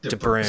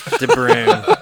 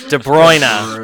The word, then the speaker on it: De Bruyne: Why did I just say De Bruyne? De Bruyne. De Bruyne. – The best part is you pronounced it correctly multiple times.